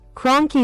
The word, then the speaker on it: Kroenke: Kroenke Sports and Entertainment.